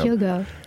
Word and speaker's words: Hyogo: Hyogo.